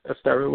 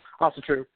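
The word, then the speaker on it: Ásatrú: Okay, I was going to ask the next question is, I have noticed that a lot of, I was pronouncing it Asteru. What's the connect for this? Ossetru.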